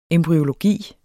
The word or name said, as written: embryologi